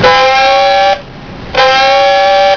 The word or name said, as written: moderndive